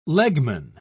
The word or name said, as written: legman